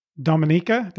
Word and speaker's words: Dominica: And I think that's interesting what you just mentioned too, because if you look on a map, Dominica, did I say it right this time? – Yep.